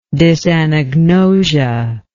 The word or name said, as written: dysanagnosia